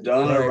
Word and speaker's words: Donna: And Donna rhymes with Toronto.